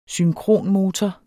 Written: synkronmotor

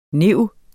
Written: NIV

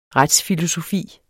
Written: retsfilosofi

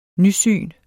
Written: nysyn

